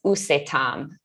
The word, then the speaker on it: UCETAM: And another one that I did was USETAM. So that's spelled U-C-E-T-A-M.